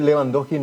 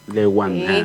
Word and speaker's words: Lewandowski: Por eso se dice Lewandowski, no Lewandowski. – Lewandowski.